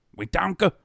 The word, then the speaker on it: Wetumpka: So, spelled Wetumpka. – But for one guy that I knew. – But pronounced Wetonka. – Pronounced like Wetonka. Wetonka!